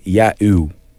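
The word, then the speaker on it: Yaeow: This is an artist called Ya'u.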